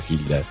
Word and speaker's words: Gilda: No, no sé quién sea, Gilda o Gilda, aquí se pronuncia Gilda, con ese tema que se llama No es despedida.